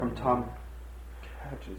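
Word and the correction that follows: Cech: Check is how it's pronounced.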